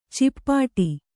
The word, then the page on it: ಚಿಪ್ಪಾಟಿ: cippāṭi